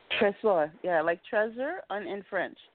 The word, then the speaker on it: Trésor: Is it Trésor? – Trésor, yeah, like treasure in French.